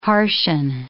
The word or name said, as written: harshen